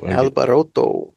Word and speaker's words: Alboroto: Alboroto. – Yeah, you have to like pronounce the T.